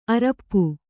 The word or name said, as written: ಅರಿಪೂ